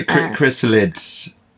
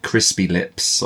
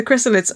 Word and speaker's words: Chrysalids: So Chrysalids. Because I'm going to keep mispronouncing this, like crispy lips or something. The crispy lips. That sounds creepier. – The Chrysalids, or just to be different, I'm reading an American copy from the 1970s, which instead is called Rebirth.